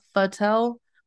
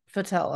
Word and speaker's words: Futel: they're pronouncing it fatel, but it's FUTEL, and I'm thinking it's futel.